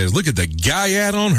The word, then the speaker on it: gyatt: Gaiat. – Gaiat means butt. – G-A-E-T? – G-Y-A-T-T. – G-Y-A-T-T. – When that's what you Gaiat. – Call... – Gaiat. – Gaiat. It's pronounced Gaiat.